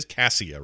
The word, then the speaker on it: Cassia: Is it Cassia is how we're supposed to pronounce it? It is Cassia, right? – Yeah. – Like Cassiopeia, but without the opia in it. Anyway. – Yes, exactly. Say Cassiopeia, then remove the opia, and you got it.